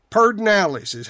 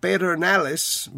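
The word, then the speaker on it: Pedernales: And about noon today, Parks and Wildlife said the Pardinalis. That's how we pronounce it here. Pardinalis, like Refugio, which should be Refugio, and Pedernalis.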